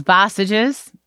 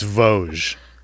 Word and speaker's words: Vosges: Would you like to know the actual way to pronounce the company's name? – Yes. – And just how far away it is from what you said? – I know. It was three years away. – It's Vosges.